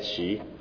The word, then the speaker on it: Hsu: My name is Ed Shi, last name HSU, pronounced Shi, like he or she, another University of Texas faculty here.